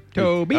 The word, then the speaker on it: Tobe: Toby Hooper. You don't know who Toby Hooper is? – Toby Hooper. It's only, there's no Y. – I mean, he goes T-O-B-E. – By Toby.